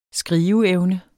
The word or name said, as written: skriveevne